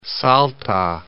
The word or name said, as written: Salta